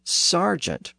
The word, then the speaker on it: sergeant: This also explains why that initial vowel sound in sergeant is spelled with an E and not an A. The word is spelled S E R G E A N T, and that's because the letter E represented a short E sound in Middle English.